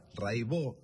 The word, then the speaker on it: Raibaut: Se escribe Raibaut, métanse, búsquenla, Rai con Y, Baut con B larga. Catalina Raibaut, Raibó se dice.